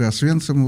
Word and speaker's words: Освенцим: В этот день был освобожден Аушвиц или Освенцим в нашем произношении.